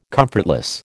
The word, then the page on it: comfortless: American English